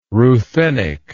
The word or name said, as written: Ruthenic